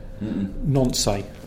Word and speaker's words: Nancy: Nancy. – Nancy. – Excellent pronunciation as well. – Nancy, yeah. Not Nancy. Yeah.